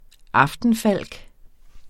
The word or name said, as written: aftenfalk